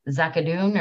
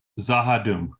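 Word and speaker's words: Z'ha'dum: And they are the people that they encountered over at, is it Zakadoon or how do you say that again? Zakadoon? – Zahadoom. – Zahadoom. No CH. Yeah. So Zahadoom.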